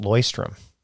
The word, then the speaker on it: Leuchtturm: By the way, I learned that it's pronounced Leuchtturm.